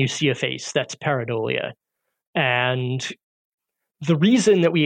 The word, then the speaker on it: pareidolia: You use the analogy to, I'm not sure I'm going to pronounce this correctly, pareidolia? – Pareidolia, yes.